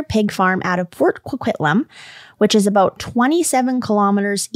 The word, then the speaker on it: Coquitlam: We can say Coquitlam.